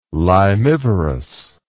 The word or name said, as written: limivorous